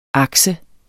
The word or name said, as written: akse